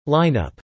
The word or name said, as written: lineup